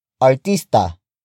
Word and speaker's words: artista: Word for word, it's artista, ba, siya. Artista, ba, siya. Artista, ba, siya. The first word is artista. Artista. Artista. Artista is spelled as A-R-T-I-S-T-A.